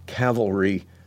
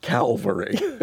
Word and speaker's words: cavalry: In 2007, he deployed to Iraq, serving in Diyala Province as Squadron Judge Advocate for the 2nd Squadron, 3rd Armored Cavalry Regiment. David, I always have to be careful with that because preachers find it impossible to pronounce cavalry. We always want to pronounce it Calvary.